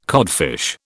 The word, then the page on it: codfish: British English